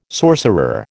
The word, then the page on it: sorcerer: American English